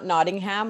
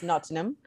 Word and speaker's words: Nottingham: It's not Nottingham like I called it when I first went there. It's Nottingham. You don't pronounce every syllable in the word.